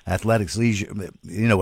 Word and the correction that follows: athleisure: From that standpoint, athleisure. – Yeah, that's it. Yeah, the ick is out of there. Just take the ick right out of that and then make things, athleisure.